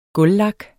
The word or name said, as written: gulvlak